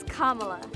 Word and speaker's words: Kamala: It's not Kamala. – It's Kamala. – Kamala Harris. – Kamala's for our schools. – Kamala's for affordable child care. – That's Kamala.